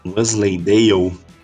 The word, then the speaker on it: Wensleydale: Aí o Last Train to Wensleydale. Como é que fala isso? – Wensleydale. – É, alguma coisa por aí, né?